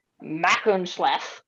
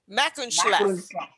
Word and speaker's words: Machynlleth: So the route goes from, as far as I can tell, the correct pronunciation is Machynlleth to Conwy. – What was the first town? – Machynlleth.